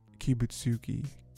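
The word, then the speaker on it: Kibutsuji: It ends because Tamayo tricks Susamaru into saying Kibutsuki's name. That's how you say it.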